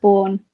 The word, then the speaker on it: Baughan: It's actually pronounced Bourne. It's really easy.